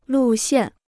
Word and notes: 路线: lù xiàn

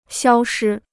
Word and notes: 消失: xiāo shī

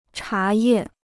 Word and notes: 茶叶: chá yè

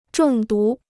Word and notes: 中毒: zhòng dú

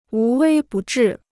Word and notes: 无微不至: wú wēi bù zhì

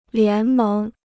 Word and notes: 联盟: lián méng

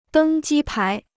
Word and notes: 登机牌: dēng jī pái

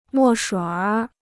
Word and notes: 墨水儿: mò shuǐ r; erhua

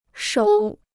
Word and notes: 首: shǒu

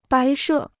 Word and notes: 摆设: bǎi she

